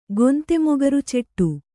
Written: ಗೊಂತೆಮೊಗರುಚೆಟ್ಟು

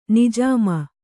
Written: ನಿಜಾಮ

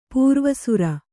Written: ಪೂರ್ವಸೂರಿ

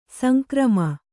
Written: ಸಂಗ್ರಾಮ

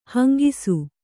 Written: ಹೆಂಗೂಸು

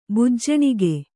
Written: ಬಿಜ್ಜಣಿಗ

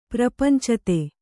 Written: ಪ್ರಪಂಚತೆ

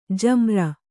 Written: ಜಾಮಾರು

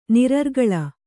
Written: ನಿರರ್ಗಲ

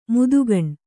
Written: ಮೋದೀಖಾನೆ

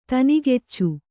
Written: ಧೇನುಕುಚ

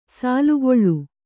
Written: ಸಾಲುಗೊಳ್ಳು

